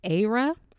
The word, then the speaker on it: Ayra: So the song is called Bloody Samaritan, and I believe her name is pronounced Aira. It's Ayra Starr, and the song is called Bloody Samaritan.